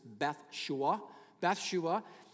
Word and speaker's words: Bathsheba: And you see a couple of wives here, Bathsheba. Proper pronunciation, actually, Bathsheba.